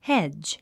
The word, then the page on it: hedge: hédʒ; ヘジ